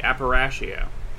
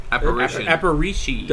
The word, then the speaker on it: Aparicio: Aparicio. – Aparicio.